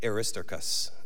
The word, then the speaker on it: Aristarchus: I actually looked it up this morning on Google how to pronounce it. And I wrote it phonetically in my notes, so it's Aristarchus. Aristarchus.